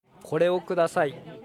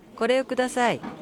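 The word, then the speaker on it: Коре о кудасай (これをください): «Коре о кудасай». – «Коре о кудасай». – «Коре о кудасай». – А теперь попробуйте сами. – «Коре о кудасай».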